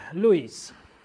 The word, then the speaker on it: Lewis: To powiedział Louis. Lewis pisze się,